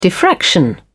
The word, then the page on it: diffraction: British English